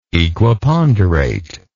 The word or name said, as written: equiponderate